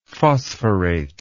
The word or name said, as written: phosphorate